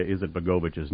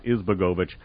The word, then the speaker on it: Izetbegovic: Alia is, by the way, I mispronounced Alia Izetbegovic's name. I left out a syllable. It's Izetbegovic, not Izbegovic.